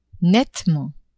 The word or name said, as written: nettement